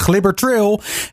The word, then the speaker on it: Glibbertreel: Wat er in de regio zeker ook gaat gebeuren, dat is de Glibber Trail. Of eh, ja, je kan dat bijna als leidenaar niet zo uitspreken. Het is natuurlijk de Glibber Trail.